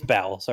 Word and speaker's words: Bowles: Calling him, I know it's like, listen, it's Bowles, all right? Fuck you. It's not a bit. – I actually don't know how do you say his name correctly? – I think it's Bowles. – Bowles? That makes sense, but why is there an E in it? – I don't know, dude.